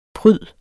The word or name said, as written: pryd